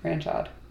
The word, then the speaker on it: Ranchod: Like ranch and OD at the end. Ranchod. – Ranchode. Got it. – There's no E though. – Uh, the the E is silent.